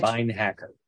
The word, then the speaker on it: Beinhaker: Probably said that wrong. Say it for me, Mitch. – Beinhaker. – Beinhaker. See, it was much easier than what I just made it.